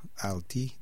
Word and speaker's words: Althea: donc A-L-T-H-E-A, Alti, The Art of Tree.